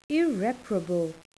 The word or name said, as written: irreparable